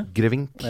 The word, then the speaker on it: Borch-Grevink: Eh, så hadde du Christian Borch-Grevink, hvis det uttales riktig. Borch-Grevink.